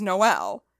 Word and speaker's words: Noel: However, my maiden name slash soon to be just like my last name is Noel, but it's spelled exactly the same as N-O-E-L because that's how you say Noel. That's how you spell it. That's how you say it.